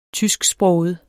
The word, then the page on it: tysksproget: -ˌsbʁɔːwəð; -ˌsbʁɔˀwəð